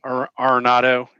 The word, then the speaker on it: Arenado: I really considered Arenado.